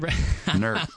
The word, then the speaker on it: Newark: You're saying it wrong. – It's the old longer. – Nerk. – I'll let you say Nerk.